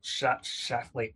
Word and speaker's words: Schlafly: But you also, in Jesus and John Wayne, like Phyllis Schlafly, am I saying her name right? – Schlafly. – Yeah, Schlafly.